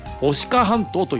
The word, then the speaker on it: 牡鹿半島: でその出っ張っている半島、多分地図で見るとほとんど自然ばかりの緑で書かれると思いますが、オスの鹿、牛辺に土ですよね、と書いて牡鹿半島と読みます。 – はい。 – ここではクジラで有名だった鮎川をはじめ、いくつかの漁村集落、そしてお金が貯まると評判の金華山もあります。 – はい。 – この牡鹿半島、女川町の周辺ではそれはもうね、たくさんの野生の鹿が繁殖しています。